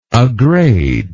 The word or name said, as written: aggrade